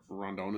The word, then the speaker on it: Rondon: You think Rondon is one of the two best pitchers in the league? – That was excellent pronunciation out of you, Rondon.